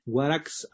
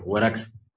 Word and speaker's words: Warax: Claro, y decíamos también, queríamos que usted nos comente sobre la incubadora cultural Warax Arte, no sé si lo estoy pronunciando bien. – Sí, perfecto, Warax con tilde, la última.